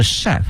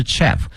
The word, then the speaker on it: chef: 同时啊，这个词呢它还是一个法语词，它的发音呢是 chef 啊，是一个法语词，不是 chef，同学们一定要听准了。